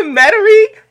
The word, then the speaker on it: Metairie: Shout out to Metairie, Louisiana. And I'm like beaming talking about Metairie because I've spent a lot of time in Metairie.